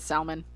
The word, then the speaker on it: salmon: Okay, so if you don't know, – I said salmon. I say walk. I don't know. I don't know why L's are silent. – It's that silent L that fucks.